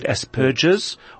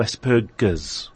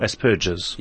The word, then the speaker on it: Asperger's: Is it a hard G or a soft G? A G or a J? Is it Asperger's or Asperger's? – The first one, Howard. – Asperger's.